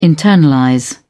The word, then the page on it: internalize: British English